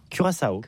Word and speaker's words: Curaçao: Donc comment on prononce ça? – Curaçao.